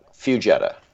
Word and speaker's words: Fugetta: It's pretty close. It's like the car. It's like the car Jetta. Fugetta.